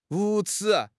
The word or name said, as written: 无耻